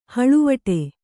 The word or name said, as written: ಹಳುವಡು